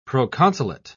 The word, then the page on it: proconsulate: British English